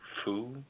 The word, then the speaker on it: pho: It's a play on a type of soup, which is spelled P-H-O. – P-H-O. – But it's pronounced foo or pho. – Pho. The restaurant called itself by name by name of the soup, followed by the words Keene, great.